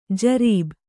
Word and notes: ಜರಬು: jarabu

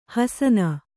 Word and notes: ಹಸನ: Kannada

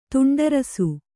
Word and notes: ತುಂಡರಿಸು: Kannada

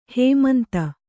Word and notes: ಹೈಮಂತ: haimanta

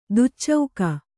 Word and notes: ದುಚ್ಚೌಕ: duccauka

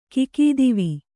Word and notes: ಖಗತ್ವ: khagatva

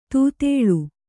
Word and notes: ತೊದಳ್: Kannada

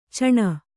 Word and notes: ಚೀಣ: cīṇa

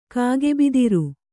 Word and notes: ಕಾಗೆಬಿದಿರು: kannada

